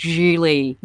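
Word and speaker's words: Gigli: So yes, that movie is, I've put out this warning to everyone, and efforts of practicing safe cinematic viewing habits, do not watch Geely. That rhymes with really.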